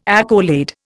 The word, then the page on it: accolade: Indian